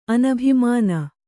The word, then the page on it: ಅನಭಿಮಾನ: anabhimāna